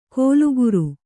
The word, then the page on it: ಕೋಲುಗುರು: kōlu guru